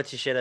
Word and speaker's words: Teixeira: Like Glover Teixeira has been in the UFC for such a long time.